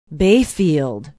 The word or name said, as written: bayfield